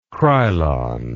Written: Krylon

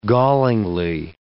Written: gallingly

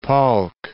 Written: Palk Strait

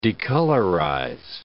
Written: decolorize

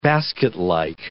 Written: basketlike

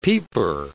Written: peeper

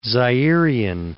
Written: Zairian

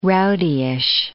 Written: rowdyish

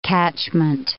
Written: catchment

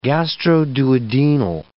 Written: gastroduodenal

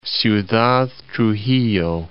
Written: Ciudad Trujillo

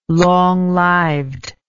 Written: long lived

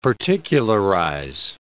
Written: particularize

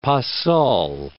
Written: pas seul

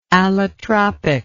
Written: allotropicity

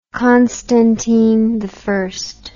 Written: constantinian

